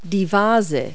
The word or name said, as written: vase